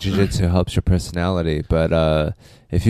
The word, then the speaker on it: jiu-jitsu: How do you pronounce it? – Jiu-jitsu. – Jiu-jitsu.